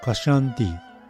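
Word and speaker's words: kṣānti: 梵语呢念作 Kashanti，那汉语呢我们读作颤提。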